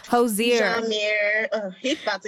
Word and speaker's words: Hozier: I say Hoiser, but how do you pronounce his name? – Hozier.